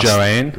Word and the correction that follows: Joan: Last lap, Joan, mate. It's pronounced Joan.